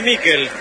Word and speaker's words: Miquel: ¿Cómo le decimos? ¿Michael o Miquel? Nicolás Álvarez, usted que es especialista en nombre. Se escribe Miquel. ¿Cómo le digo? – Decile Miquel.